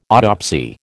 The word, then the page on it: autopsy: American English